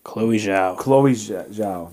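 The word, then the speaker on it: Chloé Zhao: Chloe Zhao.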